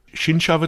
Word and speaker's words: Shinsha: Shinsha Shinsha wird es ausgesprochen.